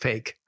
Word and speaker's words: PHEIC: PHEIC, pronounced fake.